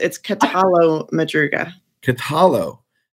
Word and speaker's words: Catallo-Madruga: It's Catallo-Madruga. – Catallo. Yep. So it's not yo. It's not like in Spanish, the LL would be yo. It's Catallo-Madruga. Yep.